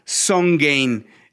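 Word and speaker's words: SongGain: Así que eh, yo les quiero presentar una plataforma que se llama Songain. Eh, que eh, es como Song y también como Gain, pero se escribe Song Gain.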